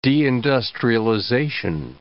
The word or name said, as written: deindustrialization